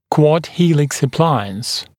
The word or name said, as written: quad helix appliance